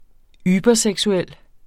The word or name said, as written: überseksuel